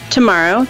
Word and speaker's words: Tomaro: Tomorrow, to make my mother happy, I will spell that for you, T-O-M-A-R-O.